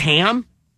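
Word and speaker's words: Hamb: The guy's name is Ham. It's H-A-M-B. The B is silent.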